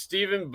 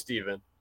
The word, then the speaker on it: Stefan: Well, it's pronounced Steven, but I've always had that problem even growing up. You know, people pronounce it. It looks like how it would be spelled, but yeah, it's just Steven.